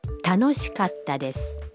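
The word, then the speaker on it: Tanoshikatta desu (楽しかったです): Tanoshikatta desu.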